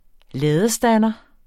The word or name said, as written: ladestander